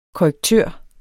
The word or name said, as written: korrektør